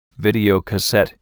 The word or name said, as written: videocassette